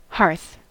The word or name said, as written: hearth